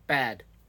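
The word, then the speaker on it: bad (בד): The Hebrew word is pronounced bad. That's right. The Hebrew word for alone is bad, B-A-D.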